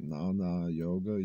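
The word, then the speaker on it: Gnana: They call it Nana Yoga. You can look into that on your own if you're curious. It's actually spelled with a G, G-N-A-N-A. But it's actually a type of yoga that's not physical. It's not hatha. Hatha, A-H-A-T-H-A.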